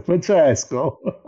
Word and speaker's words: Francesco: So my real name is Francesco. Anyway, Francesco. – Well, I'll try to use all of them. Francesco. Is that the good pronunciation? – Yes, Francesco.